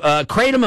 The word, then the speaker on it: Kratom: K-R-A-T-O-M? – Well, it depends on how fancy you are. I've heard it called Kratom by some people, but where I come from, we call it Kratom.